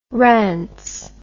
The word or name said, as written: rance